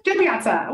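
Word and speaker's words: Jo Piazza: Joe Piazza.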